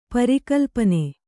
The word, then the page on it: ಪರಿಕಲ್ಪನೆ: kannada